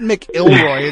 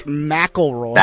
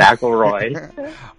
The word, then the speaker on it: McIlroy: It's not McIlroy, it's McIlroy. – McIlroy.